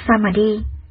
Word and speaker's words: Samadhi: Tam muội tiếng Ấn Độ nguyên âm đọc là Samadhi.